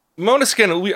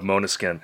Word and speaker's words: Måneskin: Måneskin, I was looking at their appearance on the Ellen DeGeneres show, and she pronounced it like that. So, going with the Ellen pronunciation of Måneskin.